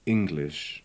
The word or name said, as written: English